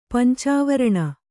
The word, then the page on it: ಪಂಚವರ್ಣ: kannada